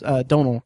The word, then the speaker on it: Domhnall: I mean, in Domhnall, I think it's pronounced Domhnall Gleeson, actually.